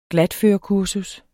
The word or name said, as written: glatførekursus